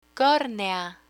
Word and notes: cornea: Spanish

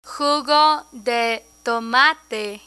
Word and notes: jugo de tomate: Spanish

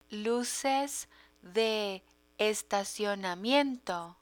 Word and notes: luces de estacionamiento: Spanish

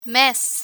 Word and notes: mes: Spanish

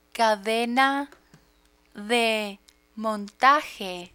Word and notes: cadena de montaje: spanish

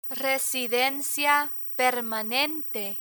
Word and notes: residencia permanente: spanish

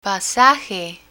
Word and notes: pasaje: Spanish

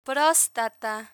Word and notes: prostata: Spanish

